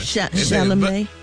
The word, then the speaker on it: Chalamet: Now, they said, honey, Rihanna and A$AP Rocky were also spotted, y'all, at the match, honey, and they said Timothée Chalamet and, honey, Kylie Jenner. – Chalamet.